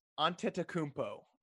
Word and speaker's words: Antetokounmpo: Giannopoulou? – Very close. Antetokounmpo.